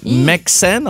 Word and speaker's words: MXene: C'est un nom tellement bizarre que j'ai la misère à le prononcer, du MXN, Mexen, en tout cas, c'est M-X-E-N-E.